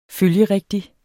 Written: følgerigtig

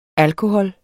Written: alkohol

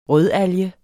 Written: rødalge

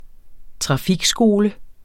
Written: trafikskole

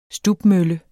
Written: stubmølle

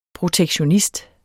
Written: protektionist

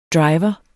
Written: driver

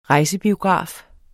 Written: rejsebiograf

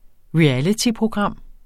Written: REALITYPROGRAM

